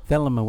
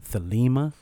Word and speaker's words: Thelema: Thelema was there. Now, depending on who you ask, some people pronounce it Thelema. They say it's a Greek word.